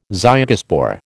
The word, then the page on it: zygospore: American English